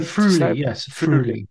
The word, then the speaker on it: Friuli: you might have to help me with the pronunciation here, but Friuli, is that Friuli.